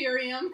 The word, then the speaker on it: Ephraim: Your pronunciation is fantastic because I had no idea how to say that word. – Yeah. I say Ephraim.